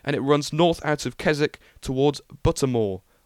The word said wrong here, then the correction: Buttermere: Oh no, Buttermere. There we go. I said it wrong.